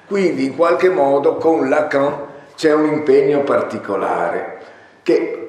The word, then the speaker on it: Lacan: Noterete la pronuncia che sottolinea correttamente la nasalizzazione con cui tutti i francesi pronuncierebbero il nome Lacan senza avere la sensazione di stare, come possiamo dire, ostentando una pronuncia.